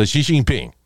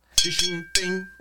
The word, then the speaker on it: Xi Jinping: So Xi Jinping. – Bien. Xi Jinping.